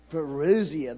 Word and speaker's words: parousia: Parousia? Parousia, the second coming of Christ.